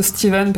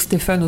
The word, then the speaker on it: Stephen: Alors je prononce Stephen parce que c'est euh, à l'américaine voilà, mais on peut dire Stephen aussi, c'est pas très grave.